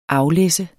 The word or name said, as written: aflæsse